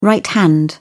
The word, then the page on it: right hand: British English